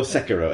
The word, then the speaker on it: Sekiro: Or Sekiro, as it actually is more correctly pronounced.